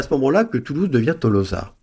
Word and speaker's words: Tolosa: Les textes grecs sont intéressants pour la prononciation, parce que les textes grecs sont écrits avec un omicron pour to et un oméga pour l'o. – Un o qui traîne. – Ce qui prouve que un o qui traîne et un o qui est fermé. Et donc la prononciation devait être Tolosa.